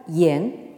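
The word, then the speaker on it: yen (言): se prononce donc en chinois moderne yen.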